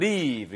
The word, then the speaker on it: leave: 발음 길게 하셔야 돼요. 이거 live 이렇게 쓰시면은 넌 살 충분한 이유가 있어. 물론 그것도 멋진 문장이지만 여기서는 leave 이렇게 길게 발음하셔야 떠나다고요.